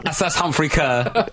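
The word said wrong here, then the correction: Ker: Carr. I always get his name wrong.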